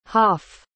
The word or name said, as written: haff